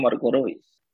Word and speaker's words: Marco Reus: belajar Jerman literal lebih tergantung juga sih kayak Marco Reus Marco Reus kan dibacanya Marco Reus ah.